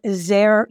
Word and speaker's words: Zare: Zare, you need to spell it correctly. It rhymes with care. It starts with a Z, Z-A-R-E.